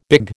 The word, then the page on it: big: American English